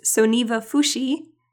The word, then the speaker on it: Soneva Fushi: I can tell you the name of the resort again, which is Soneva Fushi, which is spelt S O N E V A and then F U S H I.